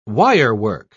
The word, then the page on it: wirework: British English